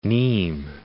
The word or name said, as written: Nimes